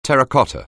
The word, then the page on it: terracotta: anglais